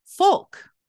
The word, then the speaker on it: Fouke: It's F-O-U-K-E. Some people say Fouke. Some people say Folk. – Like with an L?